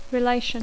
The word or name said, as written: relation